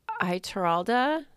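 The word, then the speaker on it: Ituralde: And there are definitely, like, there's a moment in the series where Iteralda, is that how we say his name? – Mm-hmm.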